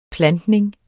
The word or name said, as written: plantning